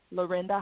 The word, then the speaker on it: Lorinda: My website is my name, Lorinda Hawkins Smith.